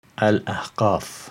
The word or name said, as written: Al-Ahqaf